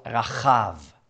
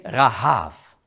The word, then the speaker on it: Rahab: Und noch etwas, im Hebräischen spricht man Hure Rahab anders aus als Rahab hier. Das sind zwei verschiedene Wörter. Also Hure Rahab, sagt man, Rahab spricht man aus Rachav. Und hier Rahav. Also das eine ist mit CH im Hebräischen und das andere nur mit H.